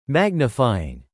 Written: magnifying

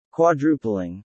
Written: quadrupling

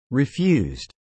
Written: refused

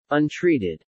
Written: Untreated

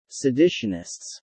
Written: seditionists

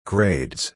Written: grades

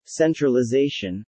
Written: centralization

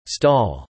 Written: stall

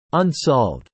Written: unsolved

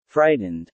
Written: Frightened

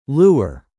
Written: lure

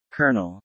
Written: Colonel

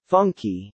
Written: Funky

